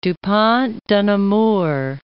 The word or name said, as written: Du Pont de Nemours